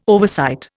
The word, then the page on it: oversight: Indian